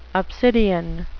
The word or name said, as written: obsidian